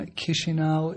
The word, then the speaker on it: Chisinau: The problem with Chisinau is that the way you say it isn't anything like the way you write it.